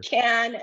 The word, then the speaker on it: Cannes: They have a little do's and don'ts, and they taught you how to say the word properly. – It's sure. – Can as in beer can.